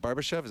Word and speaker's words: Barbashev: They were excellent, and that was, and they got, was it Barbashev? Is that how you say his name? – Yep.